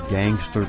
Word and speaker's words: Gangster: Next up, we've got Eddie Burns with Gangster Blues. And that is pronounced gangster, not gangsta.